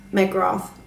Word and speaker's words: McGrath: Und zum Beispiel jemand hat dann auch gepostet auf Twitter, das ist die Titania McGrath, oder wie spricht man das aus da unten, die Titania? – Ja, McGrath, das passt.